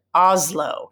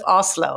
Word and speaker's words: Oslo: They said, I can't stand when Americans pronounce it Oslo instead of Oslo. I'm like, oh yeah, I guess I do say Oslo. But I also think it's because how we hear it, because the S is a little harder, right? It's like an Oslo. And so as an American, we will transfer that to a Z, you know, but that's, I'm like, oh yeah.